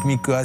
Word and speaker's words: Mika: Mais il faut faire attention, hein, parce que si vous dites Viana et Mickey, ils pourraient le prendre très mal. Donc il faut bien prononcer.